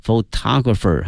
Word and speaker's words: photographer: 哎、当地的一群职业的 photographer、啊、这个词注意一下它的发音 photograph 照片 photographer、啊、拍照片的人摄影师。